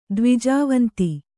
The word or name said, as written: ದ್ವಿಜವಂತಿ